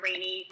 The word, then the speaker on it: Raney: Yes, you can follow me on Facebook, Instagram, Twitter, all the regular ones, Jessica Rainey. My name's spelled like R-A-N-E-Y, so it's not like Rainy Day, but it looks like Rainy.